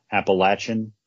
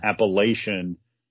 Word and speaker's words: Appalachian: Their pronunciation is Appalachian. And if you call them Appalachian, that really gets their little mountaineer blood boiling.